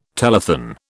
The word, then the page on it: telethon: British English